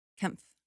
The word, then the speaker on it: Kempf: I pronounced your name correctly too, right? Kempf? – Kempf. – Kempf. – Yeah. – Okay, good. Okay, I said it perfect.